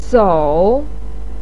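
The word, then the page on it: sou: tone 3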